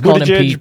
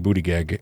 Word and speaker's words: Buttigieg: It's Buttigieg, but I like saying Buttigieg, and I know that's wrong.